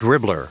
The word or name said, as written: dribbler